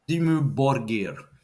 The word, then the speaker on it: Dimmu Borgir: Dimmu Borgir.